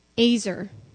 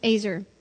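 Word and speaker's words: Azer (עֵזֶר): That word translated helper in the original Hebrew is a word that is pronounced Azer, E-Z-E-R. It's pronounced Azer.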